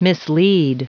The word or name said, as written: mislead